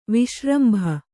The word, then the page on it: ವಿಶ್ರಂಭ: viśrambha